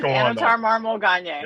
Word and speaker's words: Anatar Marmol-Gagné: My name is Anatar Marmol-Gagné.